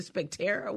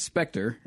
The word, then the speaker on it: Spectre: How do you pronounce this word? Because I didn't even know how to say it. I said Spectera. What is this? – It's Spectre, and it's like a ghost.